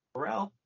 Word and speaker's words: Corral: I'm saying it right, Corral, right? – Yeah. – Corral.